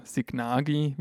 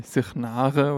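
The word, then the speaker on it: Sighnaghi: Die letzte Episode, die hatten wir ja aus Sighnare veröffentlicht, oder Sighnagi, wie man auch oft hört, vor allem aus westlichen und unwissenden Touristenmündern. Also eigentlich spricht man es wohl eher so wie Sighnare oder irgendwie so in die Richtung aus.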